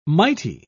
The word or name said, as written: mighty